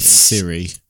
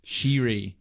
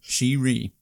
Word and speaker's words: Shiri: Shiri. Apologies if I pronounce that incorrectly. – Siri. – No, it's not Siri. – She, close. – Not Shiwi. – Shiri. Shiri. S-H-I-R-I.